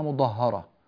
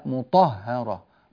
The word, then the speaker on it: مطهرة: هذا حدث معك أخي الكريم في مطهرة قرأتها مضهرة، يتلو صحفا مضهرة، لا مطهرة، مطا طا طا، هذا صوت الطاء،